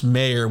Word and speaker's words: Mayer: But it's pronounced Mayer, which makes you think it's an E.